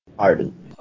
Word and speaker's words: Aden: Aden.